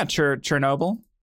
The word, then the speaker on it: Chernobyl: Yeah, Chernobyl.